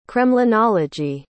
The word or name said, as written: kremlinology